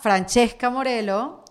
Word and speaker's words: Francesca Morello: Bueno, aquí tengo a Francesca Morelo.